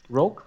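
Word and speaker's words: Rourke: Roach. Rogue? Roach? – Rogue. – Rogue. Rogue. Ja. Genau.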